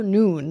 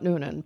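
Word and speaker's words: Noone: Yes, that would be, that would be Peter Noon, not Noonan.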